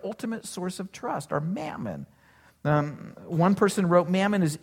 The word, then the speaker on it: mammon: The literal word that Jesus uses there is a word that's pronounced mammon, that you can't serve both God and mammon.